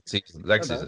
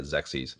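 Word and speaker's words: Xexyz: And a new Hudson Soft joint, however the fuck you pronounce this, Zezus? – I was going to say Zexes. Like Zexes is probably right. Yeah, that middle X probably needs to be enunciated. Yeah, Zexes.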